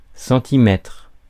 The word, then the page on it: centimètre: [sɑ̃.t͡si.mɛtʁ]; /sɑ̃.ti.mɛtʁ/